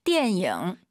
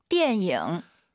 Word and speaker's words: 电影: 电影. – 이 단어 참 제가 많이 가져오죠 선생님. – 是的. – 초급자분들께서 디엔잉 니은 발음으로 많이 해서 그래요. 디엔잉 잊지 마시고요. 한번 더 들어볼게요. – 电影.